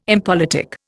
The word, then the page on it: impolitic: Indian